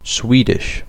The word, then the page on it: Swedish: British English; /ˈswiːdɪʃ/